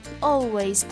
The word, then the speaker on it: always: 好，那今天呢，我们学习的就是 always，大家呢特别要小心一下它的一个发音啊，就是 o 这个发音 always。好的，那今天内容就到这里了，See you next time，拜拜。